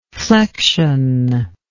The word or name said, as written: flexionless